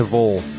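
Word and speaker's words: Vhöl: Hey, if anyone's wondering, in the background we were just smoking a vole, and so, before the show, and so now we're listening to vole, which is V-H-O-L. That's how you pronounce it.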